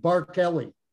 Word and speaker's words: Barkeley: but don't think Barkeley, think of Barkeley, the way the name is spelt. – Yep. – Because there are two E's, Barkeley.